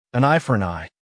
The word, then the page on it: an eye for an eye: American English